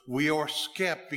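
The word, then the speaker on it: weorþscipe: it's an oddity of Anglo-Saxon language, the SC is pronounced SH, and so weorth skip became worship along the way.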